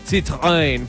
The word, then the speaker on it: Citroën: Vamos a tener por allá Honda, vamos a Zanautos, Citroën con Alcala Motor, perdón, lo dije mal. Citroën, porque Citroën es una marca francesa.